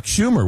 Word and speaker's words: Schumer: I believe that was Chuck Schumer, was it not? – Yes, Yes. The senator's name rhymes with rumor.